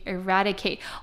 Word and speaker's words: eradicate: eradicate，或者是说要消除犯罪率，我们就会说是 eradicate crime， eradicate。好，那来看它的发音，重音在 RA 的地方发 A 的音 ,ERA,DI 发短音 ,ERADE, 那 CATE 则是发 KATE,ERADICATE,ERADICATE, 那它的名词呢则是 eradication,eradication 则是消灭。